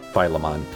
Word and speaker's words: Philemon: Philemon. I like the emphasis on the mon. – Yes, like Digimon or Pokemon.